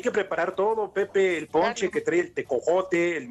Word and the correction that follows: tejocote: tejocote y membrillo, güey. Pronuncia correcto.